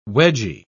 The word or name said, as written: wedgy